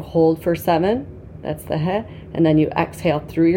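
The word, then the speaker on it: Yod he (ה): So that's the first he. Is that how you say it? – Yod. Yod he. he.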